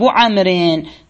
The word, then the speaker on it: عمرو: هنا عمر الواو لا تلفظ، وإنما كانوا يفرقون بين عمر وعمر، عمر وعمر. عمر ليس فيه واو، عمر فيه واو، حتى يفرقون وضعوا هذه الواو. البعض يقرأ عمرو، لا، هي حقيقة عمر، عمر وعمر في نحو قولك مررت ببكر وقولك هذا كتاب عمر.